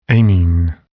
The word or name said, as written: amine